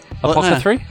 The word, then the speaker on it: apothecary: But then when he gets back to this pharmacy, I keep calling it a pharmacy. – Apothecarist. Apothecary. Apothecary. – Yes. – This apothecary. Apothecary? – No, I said it right. – What was it? – Apothecary. – Apothecary. – Yeah. – This apothecary. – English much? – Say it again. – Apothecary. – Apothecary. – Yes, you said it right.